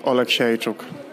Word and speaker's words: Oleksiejczuk: Can you give us that name, sir? – Michał Lord Oleksiejczuk. Michał Lord Oleksiejczuk.